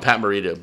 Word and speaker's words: Morita: Pat Morita.